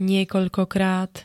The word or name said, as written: niekoľkokrát